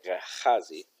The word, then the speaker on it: Gehazi: I don't remember how Gehazi, okay, Gehazi is spelled Het, so it's a guttural, Gehazi.